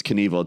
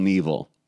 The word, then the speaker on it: Knievel: A lot of people think it's Knievel. It's Evel Knievel.